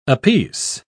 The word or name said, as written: apiece